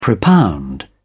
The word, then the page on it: propound: British English